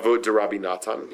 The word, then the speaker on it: Avot D'Rabbi Natan: At 13, teaches Avot D'Rabbi Natan. Am I pronouncing that right? – Avot D'Rabbi Natan, yeah.